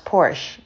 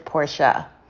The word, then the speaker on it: Porsche: Some people think that it's pronounced Porsche, but if you actually go to Germany where the cars are made, they pronounce it Porsche.